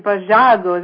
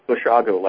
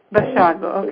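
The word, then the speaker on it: Basiago: Andrew Bajago, is that right, pronunciation? – Well, it's actually Bashago, like Chicago. – Bashago, okay.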